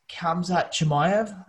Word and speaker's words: Khamzat Chimaev: Number four on my list is Khamzat Chimaev. Pronounced that right. Khamzat Chimaev, who is a ridiculous up-and-coming contender.